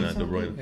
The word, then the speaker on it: De Bruyne: Listen. De Bruyne. – Yeah. Listen. – The Dutch pronunciation.